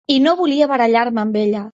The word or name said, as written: barallar